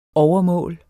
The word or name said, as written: overmål